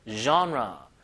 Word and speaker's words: genre: Music genre. 이게 이제 그 영어원의 단어가 아니어가지고 장르란 발음이 이제 genre, genre, music genre.